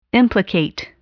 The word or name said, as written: implicate